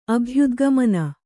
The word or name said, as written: ಅಭ್ಯುದ್ಗಮನ